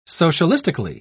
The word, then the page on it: socialistically: British English